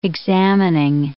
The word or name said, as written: examining